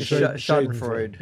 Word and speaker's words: Schadenfreude: Schnaffenfreude. The German Schadenfreude. – Yeah.